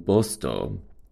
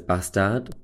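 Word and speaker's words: Båstad: in Bostor, which you might know as Bastad, but that's how you say it, I learned whilst I was there.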